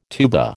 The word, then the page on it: tuba: American English